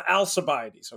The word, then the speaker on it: Alcibiades: And for those wondering, giving me a hard time because I punted on the pronunciation, Alcibiades. It's the Alcibiades, okay?